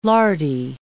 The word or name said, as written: lardy